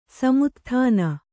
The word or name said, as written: ಸಮುತ್ಥಾನ